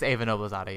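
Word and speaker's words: Eva Noblezada: I always feel I'm mispronouncing her name. – I think it's Ava Noblezada, yeah.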